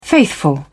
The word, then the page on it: faithful: British English